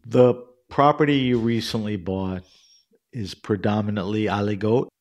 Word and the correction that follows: aligoté: or it's aligoté.